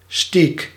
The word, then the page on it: bifteck: [bif.tɛk]